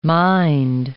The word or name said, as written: mind